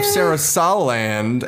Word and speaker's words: Sarasaland: She's the princess of Sarasaland. I think it's how you say it. – Yeah, that was right.